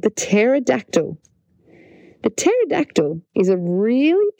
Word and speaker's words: pterodactyl: It's got a silent P. Pterodactyl is spelled P-T-E-R-O-D-A-C-T-Y-L. Pterodactyl. There you go. The P is silent.